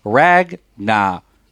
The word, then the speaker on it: Ragnarok: Then we'll talk more about Thor Ragnarok. Am I saying that right? – You're saying it right.